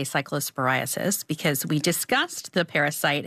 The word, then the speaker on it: cyclosporiasis: If you are worried, there is a CDC cyclosporiasis. I didn't pronounce that right. – Outbreak cyclosporiasis. Page.